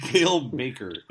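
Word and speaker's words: Cale Makar: Kale Maker is correct.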